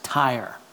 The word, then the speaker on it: Tyre: I promise you that's the way it's pronounced, Tyre.